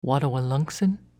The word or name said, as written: Wattawaluncksin